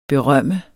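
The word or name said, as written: BERØMMEDES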